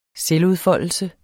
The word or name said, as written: selvudfoldelse